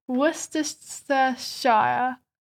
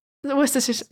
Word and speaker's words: Worcestershire: There's no direct measurements for the next bit, but you need some garlic powder, pepper, salt, and then Worcester sauce, or like Worcester, how do you say it? Worcestershire. You know the sauce? – No. – Worcestershire, yeah.